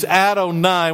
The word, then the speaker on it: Adonai: And this Adonai, we'll go to the next slide there. We'll see that it's, we pronounce it as Adonai, but it is Lord or Master.